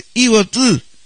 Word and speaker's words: Ibotí: Y yo creo que uno de los mayores exponentes del chamamé maceta es el conjunto Ibotí, que en sí la pronunciación es Ibotú, sin la I.